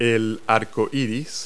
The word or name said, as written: rainbow